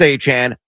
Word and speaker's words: Achane: Again, it is pronounced Travis Achane. Look it up, folks, if you don't agree. It was straight from his mouth, not mine or Pat's.